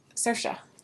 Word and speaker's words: Saoirse: I like Saoirse Ronan decently. And once you learn how to say her name. Like Inertia. – Saoirse.